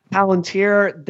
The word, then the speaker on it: Palantir: As we were not recording last night, a story was breaking about Peter Thiel and his, how do you pronounce it? – Palantir.